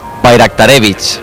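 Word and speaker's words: Bayraktarević: El Bayraktarevich. – Ese mismo. A ver, ¿cómo es? – Ese mismo. – ¿Cómo decías tú, Fermín? – Bayraktarevich. – Bayraktarevich. O sea, la J la conviertes en ahí, Bayraktarevich.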